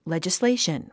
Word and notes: legislation: lèdʒisléiʃən; レジスレイション

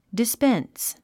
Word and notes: dispense: dispéns; ディスペェンス

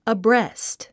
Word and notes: abreast: əbrést; アブレェスト

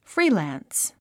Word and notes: freelance: fríːlæ̀ns; フリーランス

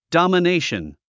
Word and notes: domination: dὰmənéiʃən; ドマネェション

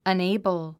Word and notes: unable: ʌnéɪbl; アネイボォ

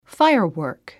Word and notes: firework: fáiərwə̀ːrk; ファイアワーク

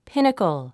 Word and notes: pinnacle: pínəkl; ピニコォ

